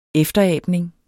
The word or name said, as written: EFTERABNING